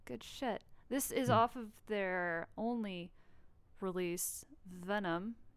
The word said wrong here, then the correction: Vanum: No, I'm butchering that. It's Latin. Vanum. Close. Oh. But no cigar. Vanum, which is Latin for vein.